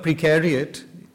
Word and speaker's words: precariat: the word precariat rhymes with the word proletariat.